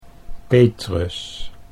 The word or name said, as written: Petrus